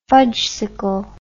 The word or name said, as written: fudgsicle